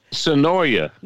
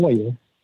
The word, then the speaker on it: Swannanoa: Sonoya. – No, not Sonoya. Swannanoa.